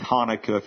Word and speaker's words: Hanukkah: We Gentiles say Hanukkah. If you're Jewish, you have it's a hard H that you don't want to pronounce when you have a cold.